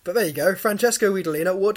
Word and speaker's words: Guidolin: Okay, it's spelt Guidolin. It's not how you say it. It's Francesco Guidolina.